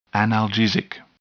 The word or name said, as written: analgesic